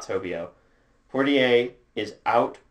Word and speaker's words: Poitier: So the first thing that he said is Taylor Portier, which is how you pronounce his name apparently, and not Poitier.